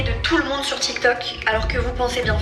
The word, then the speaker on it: TikTok: So let's learn how to pronounce TikTok. You close it with a K. Tik. Then we have talk.